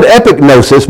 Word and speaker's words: epignosis: epi gnosis, or you pronounce it epignosis. Then you pronounce the G, it's not silent.